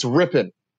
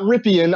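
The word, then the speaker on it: Rypien: It's Rippon. It is not Rippian. I know that's how it's spelled.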